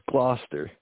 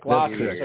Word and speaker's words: Gloucester: But is it Gloucester, Gloucester? How do you pronounce that city up there in Massachusetts? – That's Gloucester. – Gloucester? – Yes. – Am I saying it right? All right, Gloucester.